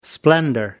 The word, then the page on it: splendour: American English